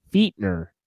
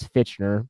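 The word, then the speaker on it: Fichtner: His name is pronounced Fietner. – Yep, Fietner. – I thought it was Fitchner.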